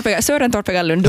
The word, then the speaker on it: Søren Torpegaard Lund: Ja, Søren Torpegaard Lund. Du hade fint danskt uttal. – Jag tycker det är Søren Torpegaard Lund.